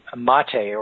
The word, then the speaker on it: MATE: And there's a Linux Mint Mate, or it's spelled Mate, M-A-T-E edition.